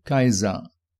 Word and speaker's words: Caesar: La vorto Kaiser devenas de la romia alnomo Caesar, pronuncita en la antikva Romo, Romio, kiel Caesar. En Esperanto, Cesaro.